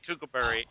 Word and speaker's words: Kookaburra: Am I saying it right? – Yes, Kookaburra. Oh, and it's featured very prominently in this particular video.